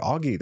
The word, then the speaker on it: Auggy: Augie, that's my nickname. But I spell it A-U-G-G-Y.